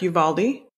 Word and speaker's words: Uvalde: And I want to say Uvalde, if I pronounced that correctly, in Texas? – Correct. Uvalde, near San Antonio.